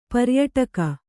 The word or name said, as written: ಪರ್ಯಟಕ